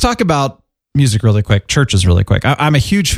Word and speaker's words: Chvrches: By the way, folks, that's churches with a V instead of a U. CHVRCHES. Go ahead. – Exactly. So it'd be like churches. – Churches. It's spelled like old English.